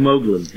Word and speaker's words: Moglen: Eben Moglen, yes.